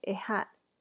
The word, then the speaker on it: ehad: La palabra una en hebreo, la pronunciación se escribe ehad, e E-H-A-D, que es la misma palabra o término en hebreo que se utiliza en el Shema, o que es el, credo de Israel.